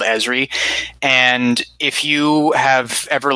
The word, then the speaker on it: Esri: No, it's Esri because it's the same as the name of the Trill symbiont who gets forcibly implanted with the symbiont against her will.